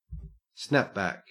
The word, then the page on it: snapback: Australian English